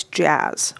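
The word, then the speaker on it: Jaz: I'm usually known as Jazz with one Z.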